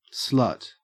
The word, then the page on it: slut: Australian English